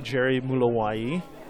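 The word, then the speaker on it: Gerry Mulowayi: You can find me under The Art of Jerry M or under my name, Jerry Mulawayi.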